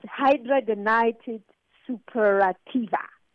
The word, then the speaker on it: hidradenitis suppurativa: It's hidradenitis suppurativa.